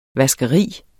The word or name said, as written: vaskeri